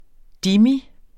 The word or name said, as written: dhimmi